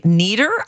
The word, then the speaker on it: Nieder: Hey, that was Eric Nieder, I think is how we pronounce his last name. It's N-I-E-D-E-R. I think that's German, and that means you pronounce the second letter.